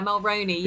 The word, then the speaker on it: Mulroney: Yeah, Mulroney, yeah.